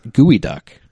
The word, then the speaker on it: geoduck: From Evergreen College in Olympia, Washington, Speedy, the Geoduck mascot. – The what duck? – The Geoduck. – Geoduck. – But it's actually pronounced Gooey Duck.